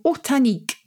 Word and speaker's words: ortanique: O-R-T-A-N-I-Q-U-E. – Oh, Hortan. Hortan. What does Hortan mean? Or Horta, and the nique is on the end, like picnic.